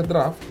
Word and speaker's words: Drft: La primera es draft, y que aunque se pronuncia draft, la manera en que se escribe es sin vocales, entonces sería DRFT.